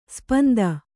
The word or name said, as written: ಶಾಪಾಂತ